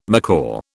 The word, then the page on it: macaw: British English